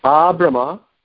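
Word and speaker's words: ābrahma: Ah, Brahma, from Lord Brahma, that long A in the beginning means from.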